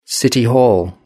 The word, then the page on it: city hall: British English